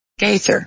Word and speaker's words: Gaither: G-A-I, Gather. – Yes, that's what my name is, G-A-I-T-H-E-R. – Oh, okay, Okay, so it's G-A-I.